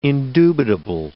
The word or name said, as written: indubitable